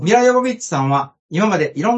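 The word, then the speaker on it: Jovovich: 名前の表記はジョヴォヴィッチですが、本人の母国キーウのウクライナ語の発音に近い、ヨ・ボビッチと呼んでね、と発言していますので、ここでは、頑張ってヨ・ボビッチと発音したいと思います。 – うん、ちなみにジョヴォヴィッチというのは英語読みだそうです。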